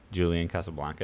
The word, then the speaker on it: Julian Casablancas: How do you say the Strokes lead singer's name? Julian Casablancas. Yes.